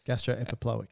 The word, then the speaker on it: gastroepiploic: And to go with your blood vessels, in that greater omentum is the two blood vessels being the gastro-mental or gastro-epiplotic. – It was worth it just for the mispronunciation of gastroepiploic.